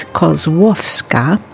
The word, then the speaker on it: Kozłowska: Before that, we heard a bunch of Stenia Kozłowska. I have to correct my pronunciation at the beginning of the set. I mispronounced it. I'm so, so sorry. It's Kozłowska. And we heard, let's see, Gdybym Ciebie nie poznała, which means if I never met you.